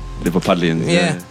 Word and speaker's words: Liverpudlians: Liverpudlians? Is that how you say it? – Liverpudlians, yeah.